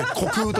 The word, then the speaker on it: 虚空: 私も虚しい空と書いて虚空と読みますけれどもちょっと2 回続いたんでね、もしかしたらマリさんが読み間違いとか噛んだとかじゃなくて本当にそう思い込んでる可能性もあったんでちょっとごめんなさい。